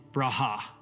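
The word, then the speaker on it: Braha (ברכה): How you say that word in Hebrew is Braha. Braha. B-R-A-H-A-H is like the pronunciation. Braha. You have to say it like with surprise.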